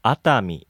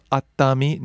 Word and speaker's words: Atami: Atami. – So it's three syllables. Atami. Nothing, no pauses or anything in there? – Mm-mm.